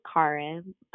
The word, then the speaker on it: Chara: So my name is Kara, but it's spelled C-H-A-R-A.